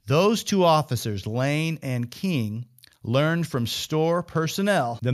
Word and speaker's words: Kueng: King, spelled K-U-E-N-G, but pronounced King, they arrived with their body cameras activated and running.